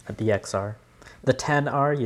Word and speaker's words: XR: 10R, obviously. – 10R, okay. – You pronounce it 10.